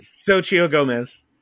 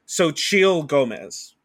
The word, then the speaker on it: Xochitl: Because I want to say Xochitl, because I know in Portuguese, the X is a ch sound, but I know that it's not.